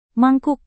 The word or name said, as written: mangkuk